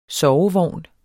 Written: sovevogn

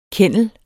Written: kennel

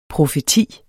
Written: PROFETIERNES

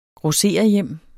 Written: grossererhjem

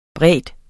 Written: brede

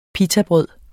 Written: pitabrød

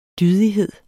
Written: dydighed

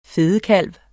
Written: fedekalv

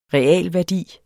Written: real

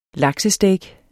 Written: laksesteak

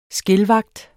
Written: skildvagt

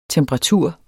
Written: temperatur

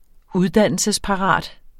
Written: uddannelsesparat

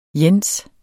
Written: jens

